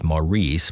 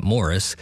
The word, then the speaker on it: Maurice: Back to the Baroque era in just a moment for music by a guy whose name looks like it should be pronounced Maurice, but he was English, so they say it Morris.